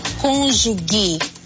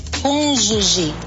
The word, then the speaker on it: cônjuge: Lembre-se, o G antes de E e I em português tem som de J. Portanto, nada de cônjuge. A pronúncia correta é cônjuge.